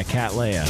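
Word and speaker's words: Kat Leia: Is that how you pronounce it? – Yeah, K-A-T. Yeah, Cat Leia.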